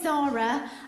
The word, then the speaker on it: Zahra: My name's Zara, you know, like the shop. But unlike the shop, my name is spelled Z-A-H-R-A. It's an Arabic name, and my Arabic family pronounce it Zahra.